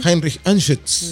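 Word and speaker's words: Heinrich Anschütz: El actor Heinrich Anschutz, usted que sabe alemán, pronunciarlo como corresponde. – Heinrich Anschutz.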